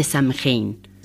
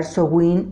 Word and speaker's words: Samhain: ¿qué te parece si nos hablas de Samhain? – Samhain, que también podemos pronunciar Sowin o Samhain, es una palabra de origen galés arcaico que significa el fin del verano.